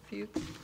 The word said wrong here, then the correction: Fuchs: It's actually pronounced Fox, F-O-X, but it's spelled F-U-C-H-S.